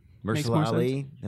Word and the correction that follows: Mahershala: Mahershala Ali. – Yeah. Mahershala.